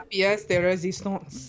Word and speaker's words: pièce de résistance: La pièce de résistance.